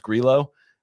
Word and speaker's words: Grillo: which no matter how many different ways CBS pronounced it today, it is pronounced Grillo, which was pretty amazed that somehow that's still a thing, but we're not ready for Hamsterdam just yet.